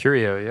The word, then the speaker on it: Curieux: So ours is the Curieux. Is that correct? Is that how you say that? – Curieux, yep.